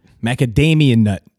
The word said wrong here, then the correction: macadamia: Macadamian, and it's macadamia. M-A-C-A-D-A-M-I-A, macadamia nut. – There's no N or M at that.